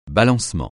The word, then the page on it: balancement: francais